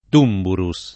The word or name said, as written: Tumburus